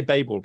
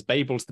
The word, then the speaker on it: Babel: I'm glad you say Babel, by the way, because that's how I pronounce it. But Rebecca F. Kwong gave the talking lecture in Oxford recently, and I met her through that, and she pronounces it Babel, and she says Babel's the British way.